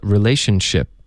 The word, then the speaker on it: relationship: 嗯，宣 ch ch ch，这里又来了一个去哪儿的 ch，relationship， OK，所以一个句子中呢，出现一模一样的这个发音技巧两次，然后重点就是因为前面的音，你的舌尖得顶上颚，连发到后面的摩擦音呢，就导致一个爆破，OK，然后这里如果 relationship。 Relationship，你听别人说的时候不熟悉这个发音技巧呢，你可能也许听到 chip 这个字了、也就是饼干、薯片，对吧？